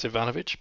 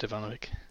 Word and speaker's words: Ivanovic: I think technically for the Eastern Europeans it's Ivanovic, but I'll accept Ivanovic.